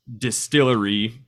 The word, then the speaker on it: DSTLRY: Did you take home a copy of The Devil's Cut from Distillery? – I did. – Okay, it's a black label size book, and it's the first offering from a new publisher called Distillery, but it's just D-S-T-L-R-Y.